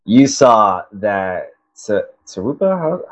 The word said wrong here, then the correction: Topuria: how's it pronounced? – Topuria.